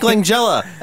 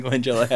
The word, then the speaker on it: Langella: I said Masters of the Universe from 1987, starring Dolph Lundgren and other important people like Courtney Cox and Frank Langella. And I'm not sure if it's a hard or a soft G. I think it's Langella.